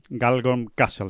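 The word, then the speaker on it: Galgorm Castle: En fin, 5 españoles de garantías y con posibilidad de que nos den una alegría este fin de semana en Galgon Castle, que creo que se dice así. – Galgon Castle, sí, bueno, pues ojalá sea así.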